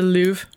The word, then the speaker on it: Louvre: Yeah, I went to the Louvre. Louvre. It's like spelled like L-O-V-R-E, yeah. Which always confuses me.